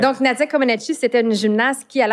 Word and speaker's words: Comăneci: Non, puis tu as parlé aussi de Nadia Comăneci. Est-ce que je prononce bien? – Non. – Comăneci.